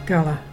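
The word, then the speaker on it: Garla: The mark of the word today is Gala. It's fire. And to spell that, there's G-A-R-L-A.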